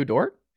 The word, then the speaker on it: Dort: Shout out to how do you say it? Lugens Dort. – Oh, Dort, Lou Dort. – Yeah, Lou Dort.